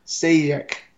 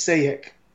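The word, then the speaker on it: Šejić: No J. The J stop. So it's Sejek.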